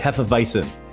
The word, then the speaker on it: Hefeweizen: Did I say that right? Hefeweizen. Hefeweizen. Yeah. I think you get the V in there.